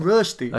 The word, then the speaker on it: Rösti: Rösti. Come on. Say it right. – I've not been to Switzerland. – It's Rösti.